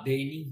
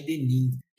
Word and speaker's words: denim: Eu aprendi a pronunciar denim, que eu sempre falei denim.